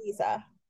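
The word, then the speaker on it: Liese: I think I'm pronouncing her name right. If I'm not, I'm so sorry. But it's, do you know how to pronounce it? – Chloe Lisa. – Lisa. – Yep. – Okay. Oh, I was really off with that.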